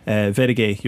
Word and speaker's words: Wedage: Your Bernard Butler and Takshila Vedige, you're both up on that, and that's spelled Wedage. Is that correct? – Yeah, that's correct. – Okay, and did I pronounce it correctly? – Yeah.